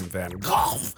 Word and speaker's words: Van Gogh: as it looks like you're traveling through a Van Gogh, or as you would pronounce it, Van Gogh painting, and it's beautiful.